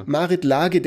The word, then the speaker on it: Marit Lage: Spricht man die eigentlich Marit Lage aus schon? Ne? Ich weiß das. – Marit Lage, glaube ich tatsächlich. – Äh, Lage? Lage. Lage. – Ja. – Die Marit Lage der Nation, wie dem auch immer sei.